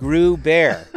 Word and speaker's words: Groubert: So that's not how I spell my last name, but that's how it sounds, because it's Grew Bear, rhymes with Pooh Bear.